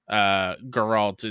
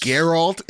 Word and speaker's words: Geralt: Geralt in The Witcher being different than yours. – Geralt. It's pronounced Geralt of Orivia.